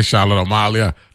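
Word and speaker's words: Charlotte Amalie: That's how they pronounce it, you know. Not Amalia and all that stuff. They say Charlotte Amalia.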